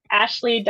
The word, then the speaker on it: Ashly: Yeah, so I'm, as far as social media goes, I'm mostly an Instagram user, Ashley.Winchester, and Ashley is spelled A-S-H-L-Y. There's no E. – No E. – Ashley. No E. Ashley.